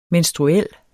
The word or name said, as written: menstruel